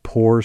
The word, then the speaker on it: Pour: The other one's called Poor Society. That's spelled P-O-U-R.